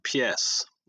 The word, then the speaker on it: pies: And the example I use in the book is the word for dog, which is like the French word pies. It's pronounced like the French word, spelled P-I-E-S.